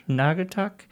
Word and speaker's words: Naugatuck: And then I was invited to one in, was it Naugatuck? Did I say that right? – Naugatuck Community College? – Naugatuck. Not the community college, it's the Park and Rec.